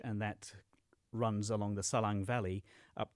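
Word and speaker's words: Salang: I wonder if you might just want to describe this, I don't know if I'm going to say it right, but the Selang Tunnel? – Perfect, yes. So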